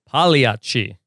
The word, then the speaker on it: Pagliacci: Pagliacci. – Pagliacci, excuse me. There's a G, but it's silent, right? – I believe so.